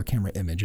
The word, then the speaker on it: CamerImage: So right now we're at CamerImage, or CamerImage, as I understand it's actually more correctly pronounced.